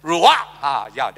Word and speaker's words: Ruach: Ruah, ruah. Or rather, it's pronounced with a guttural sound. It's so hard for me to pronounce that. Ruach. That sounds more like it.